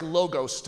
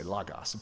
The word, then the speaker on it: logos: It looks like logos to us, but if you really want to sound smart, you can say it like a Greek person would and say logos.